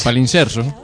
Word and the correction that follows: palimpsesto: Pronuncia bien, por favor. – Palimpsesto. ¿Qué? Palimpsesto.